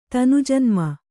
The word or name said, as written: ತನುಜನ್ಮ